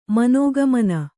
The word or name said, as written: ಮನೋಗಮನ